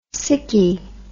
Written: sicky

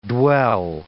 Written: dwell

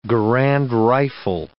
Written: Garand rifle

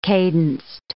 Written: cadenced

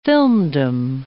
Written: filmdom

